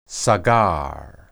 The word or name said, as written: sagar